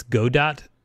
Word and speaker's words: Godot: That is a game engine that is spelled G-O-D-O-T. The people who make it, they say it's Godot, but they are wrong. It's Godot.